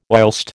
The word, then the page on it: whilst: American English